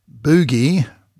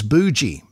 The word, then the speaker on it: bougie: It's not pronounced boogie, bougie, bougie, bougie. I think it's bougie, bougie, B-O-U-G-I-E, sort of coming from bourgeois or something like that.